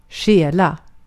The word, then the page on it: stjäla: /ˈɧɛːˌla/; /ˈɧɛːˌlɑ/